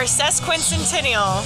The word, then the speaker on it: Sesquicentennial: I have messed this up every single time. I know how to say it. I told you, I sound dumb every Friday at 5 a.m. Congaree National Park or Sesquicentennial? That's it.